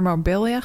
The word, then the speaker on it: Marbella: Hij wil vaker op doordeweekse dagen naar Marbella gaan.